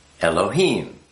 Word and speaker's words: Elohim: Aleph, you can be pronounced Ah, E, and A. Lamed is pronounced L, the L. He is, you know, the breath. Yod is pronounced E, and Mem is pronounced with an M. So, I mean, if you look at this, Aleph, Lamed, He, Yod, Mem, Al-Ha-Im, Elohim. Now, when you see just L, E-L, or sometimes L-E-E-L-I, then it becomes an A, not an E, not an A, but an A.